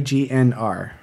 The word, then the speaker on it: Grignr: It's Grignr. – It's like a wolf with a throaty growl. – So it's like a throaty N sound with an R color to it, which I don't think exists in most languages.